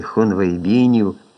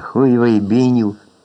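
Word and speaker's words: хунвейбіни: Усі говорили про Мао Цзедуна і Хун Вейбінів, чи, як це зазвичай вимовлялося, Хуй Вейбінів.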